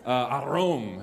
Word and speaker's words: arom: You gotta like, it's kind of a mixture of like this sexy rolling your tongue slash this like aggressive growling of a tiger. So arom.